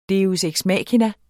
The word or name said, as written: deus